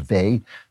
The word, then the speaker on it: Weil: You know, there's a philosopher, a religious mystic named Simone, her name is spelled Weil, but I think it's pronounced Vey, Simone Vey, she's French, and she said, imaginary evil is romantic.